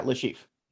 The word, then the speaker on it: Le Chiffre: We cut to a scene of I think this is where we meet Le Chiffre, right? That's how you say it. – Yeah, Le Chiffre.